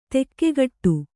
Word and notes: ದಿಕ್ಕುಗೇಡಿ: dikkugēḍi

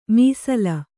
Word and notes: ಮೀಸಲ್: kannada

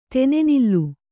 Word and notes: ತನೆನಿಲ್: kannada

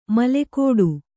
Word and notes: ಮಿಲುಕಾಟ: milukāṭa

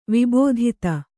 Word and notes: ವಿಬೋಧಿತ: Kannada

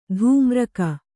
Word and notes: ತಾಮ್ರಕ: tāmraka

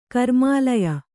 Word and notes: ಕರ್ಮಾಲಯ: kannada

